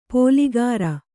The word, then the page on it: ಪೋಲಿಗಾರ: Kannada; pōligāra